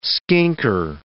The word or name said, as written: skinker